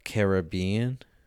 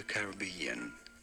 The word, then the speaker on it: Caribbean: It seemed like he said Caribbean.